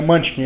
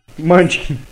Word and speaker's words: Munchkin: E alguns outros cenários de humor, assim, que eu tava vendo, tipo Munchkin, que é. Eu nem sei se é assim se pronuncia, alguém sabe? Não? – É Munchkin, é isso mesmo. – Munchkin. – Munchkin, tu falou certo.